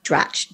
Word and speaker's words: Dratch: And so I want to welcome to the show, Helen, Fritsch, and Laurie Dratch.